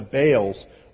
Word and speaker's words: Baal: In fact, it's actually pronounced Baal in Hebrew, and it means master.